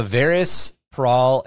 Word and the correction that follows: Vardus Praal: and is actually Vardus Praal. I just pronounced it wrong the first time.